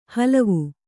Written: ಹಲುವ

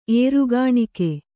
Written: ಏರುಕಾಣಿಕೆ

